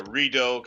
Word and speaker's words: Rideau: I don't know if I got the pronunciation right. It's either Rideau or Rideau Canal. – There is. It's called the Rideau Canal.